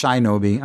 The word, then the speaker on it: Shinobi: That's pronounced Shinobi, I'm sorry.